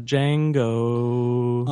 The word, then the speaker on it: Django: It's in a Django. – Unchained. – It's in a Django Unchained. – Yeah. Silent. – Oh, that's right. That's right. – Or the D is silent.